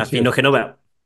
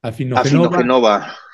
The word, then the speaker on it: Afinogenova: Afinogenova. – Afinogenova.